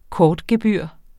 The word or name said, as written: kortgebyr